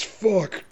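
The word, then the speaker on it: Fučík: It's F-U-C-I-K. I totally didn't see the... – I was going to say, it's that was touch and go there for a second. It's Julius Fuck. – Julius Fuck.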